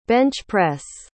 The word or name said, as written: bench-press